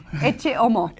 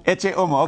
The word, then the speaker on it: Ecce Homo: How do you say it? Ecce Homo? – Ecce Homo. – Ecce Homo, okay.